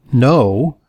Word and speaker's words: Know: Our guest is Dr. Lee No. Yes, he's Dr. No, but it's spelled K-N-O-W, which means that it's not a negative. It means that he's smart because he's in the K-N-O-W.